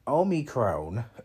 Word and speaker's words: Omicron: Well, not a few months ago, because Omarion was out, or Amori. See, I've been saying Omarion so long, I forgot how you pronounce the shit. Omicron was just out.